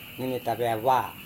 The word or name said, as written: nemeta-veava